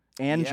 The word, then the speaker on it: Anger: His last name is spelled A-N-G-E-R. – Anger. Anger. – Anger. – Anger. – I was like, dude, your last name is Anger. – Yeah. – Own up to it. It's awesome. Anger. Anger.